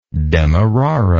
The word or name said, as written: demeraran